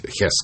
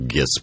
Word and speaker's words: Гесс: Это Рудольф Гесс, ну, мы так привыкли произносить Хесс, конечно. – И мы сегодня вам еще скажем, почему это важно, почему мы стоим на традиции, что Рудольф Гесс произносим их.